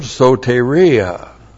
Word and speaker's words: Soteria: There is another word, Soteria. Looks like that in the Greek, S long O T long E R I A.